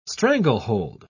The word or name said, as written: stranglehold